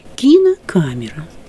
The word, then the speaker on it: кинокамера: И в любом словаре мы увидим, значит, например, там слово какое-нибудь «кинокамера». Да? «Камера» – главное ударение. А кино – побочное ударение. Оно даже ставится такое в обратную сторону, наклон этого значка. Кинокамера, да?